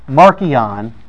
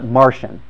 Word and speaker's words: Marcion: That's how you pronounce it in Latin. Actually, if you hear people talk about him, scholars today, they usually pronounce it Martian, as if he was Marvin's relative, I guess.